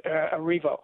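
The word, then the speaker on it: Erivo: She's played by an English-born actress, Cynthia Barino, but it's a compelling movie. It really is. I should say her name is Arrivo.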